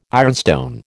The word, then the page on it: ironstone: American English